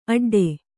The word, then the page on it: ಅಡ್ಡ: aḍḍa